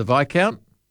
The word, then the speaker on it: Viscount: It was my first experience with the silent S, was the Viscount.